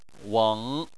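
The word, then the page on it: weng: tone 3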